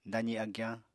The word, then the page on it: dazi agyañ: apatani